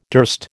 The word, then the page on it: durst: American English